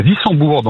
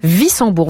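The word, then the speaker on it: Wissembourg: À Vissembourg, dans le nord du Bas-Rhin. – Voilà, je préférais que ce soit vous qui donniez la bonne prononciation. Vissembourg, vous êtes au travail, au repos, vous faites quoi ? – Je suis en vacances chez moi.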